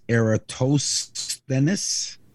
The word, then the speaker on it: Eratosthenes: His name is spelled Eratosthenes, which is pronounced Eratosthenes.